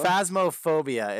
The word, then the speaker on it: Phasmophobia: Phasmophobia.